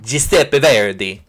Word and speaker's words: Giuseppe Verdi: Giuseppe Verdi. I'm pretty sure that's how it's pronounced in the original Italian.